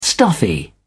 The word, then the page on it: stuffy: British English